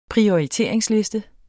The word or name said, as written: PRIORITERINGSLISTER